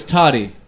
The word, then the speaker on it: Toddy: And I was like, my name is Toddy, pronounced with the Audi.